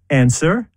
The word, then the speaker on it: answer: Or answer. – W.